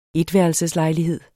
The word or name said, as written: etværelseslejlighed